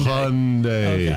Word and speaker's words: Hyundai: How do you say it? I'm sorry. – Hyundai, no. like Sunday. – Hyundai.